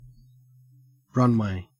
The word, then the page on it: runway: Australian English